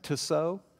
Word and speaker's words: Tissot: Tissot.